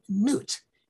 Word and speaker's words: Nut: And the most overarching concept is a concept of a deity they call Nut. I think it should be spelled N-W-T for English speakers, but English people spell it N-U-T. The French spell it N-U-I-T.